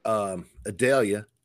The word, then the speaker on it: Adelia: We've got Adelia. And the only reason I can get that name right is because it rhymes with Vidalia.